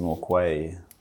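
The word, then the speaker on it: Norquay: It's like circular key, but the way that we pronounce it is Norkway.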